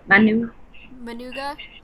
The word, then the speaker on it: Managua: We'll be flying from Amarillo, Texas to Houston and then to Manuga. – Managua? – Managua. – Managua. Okay.